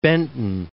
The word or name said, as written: Benton